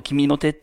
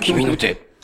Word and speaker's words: KIMINOTE: えー、君の手はアルファベットで全部大文字で KIMINOTE。と書きます。おノートだ。ノートだ。 – ええ。でも君の手って読んでください。君の手。